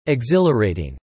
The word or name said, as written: exhilarating